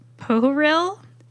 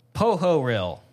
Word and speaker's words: Pohoryle: I believe it's pronounced Pohoryl. Spelled P-O-H-O-R-Y-L-L-E.